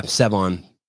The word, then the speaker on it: Sevan: How do you say your name? Is it just Sevan? – Yeah, Sevan.